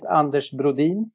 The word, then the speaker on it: Anders Broden: So in Swedish, my name is Anders Brodin, which is difficult to pronounce for you.